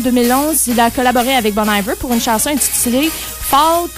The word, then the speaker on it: Bon Iver: Ouais, c'est Bon Iver. – C'est Bon Iver. Excusez-moi. – Ça fait tellement fendant. Ça fait fendant de dire Bon Iver, mais c'est comme ça qu'il prononce.